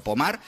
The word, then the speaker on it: Pomar: si las buscáis, sí, Pomar, se escribe Pomar, como si fuera en castellano, pero Pomar, pues allí en Francia, hablamos de unas imágenes que podían pasar.